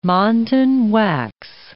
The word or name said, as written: montan wax